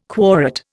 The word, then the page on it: quorate: Indian